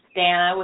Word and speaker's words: Danna: And I hate that because, you know, my name's Danna with two N's and of course no one ever got that right when I was growing up.